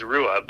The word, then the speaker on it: Ruhe: It's actually pronounced Rua, but Ruhe, uh, let's just say R U H E, uh, is directly translated means a quiet, peaceful calm.